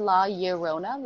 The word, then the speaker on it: La Llorona: The Curse of La Llorona. Is that right? – Yeah, I think it's La Llorona. Like, two L's make a Y sound, but that is some freshman year of high school info.